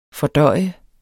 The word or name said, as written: fordøje